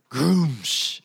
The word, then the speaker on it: Gruumsh: How do you say that? Gruumsh. – Two U's. – G-R-U-U-M-S-H.